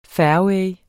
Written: fairway

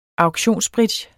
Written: auktionsbridge

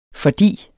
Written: fordi

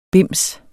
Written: bims

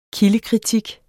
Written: kildekritik

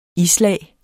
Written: islag

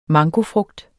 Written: mangofrugt

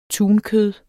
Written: tunkød

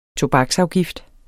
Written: tobaksafgift

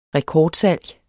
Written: rekordsalg

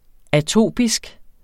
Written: atopisk

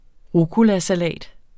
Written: rucolasalat

